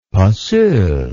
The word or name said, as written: passeul